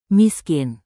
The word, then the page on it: miskin: Indonesian